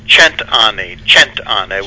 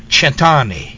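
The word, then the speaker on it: cent'anni: Centauni? – A little bit. Actually, it's an Italian toast, and it means 100 years. – Okay. – It's pronounced Centauni with a soft C. – Centauni.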